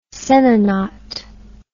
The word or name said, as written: senna knot